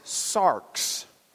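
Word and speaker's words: sarx (σάρξ): So if we got out our Greek lexicon and looked up the word flesh, what we would see is the Greek word that we pronounce sarx. I've put it in parentheses in the Greek. English spelling of equivalents right next to it. Sarx. S-A-R-X. Now, you might know that word.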